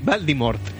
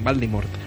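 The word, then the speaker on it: Voldemort: Eh, Valdimort. acabado en T. – y se escribe con V, Valdimort. – ¿Vale? – cuando, si lo tienes que decir algún día en el corto, pues que, sepas decirlo bien, Valdimort.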